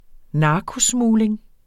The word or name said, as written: narkosmugling